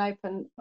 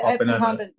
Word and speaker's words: Oppenheimer: Yeah, I'd rather see Barbie than open. What's it? Oppenheimer.